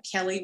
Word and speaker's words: Kelli: So you can find me on social media at I am Kelly Ware, and Kelly is spelled K-E-L-L-I, last name Ware, W-A-R-E.